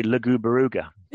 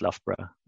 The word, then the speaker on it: Loughborough: So many people, or some people, might look at Loughborough and say Lugubaruga. – Exactly. – As they were looking at the actual words and trying to pronounce it Lugubaruga, but it's pronounced Loughborough.